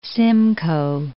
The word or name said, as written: Simcoe, Lake